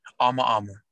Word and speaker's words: Amaama: So, did I pronounce it right? How do you pronounce it? – Amaama. – Amaama. Okay. So I was wrong.